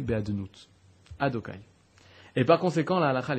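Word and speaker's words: Adonaï: Lorsque tu vois un Yud à côté d'un He, à côté d'un Vav, à côté d'un He, Yudke Vavke, la façon de le prononcer, c'est Adokai.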